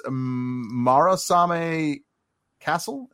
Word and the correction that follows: Murasame: Uh, Murasame.